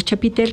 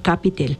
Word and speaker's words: chapitel: Pasa el tiempo y entonces deciden construir esa estructura que nosotros le llamamos chapitel, que en realidad es una palabra que se debe de pronunciar como capitel.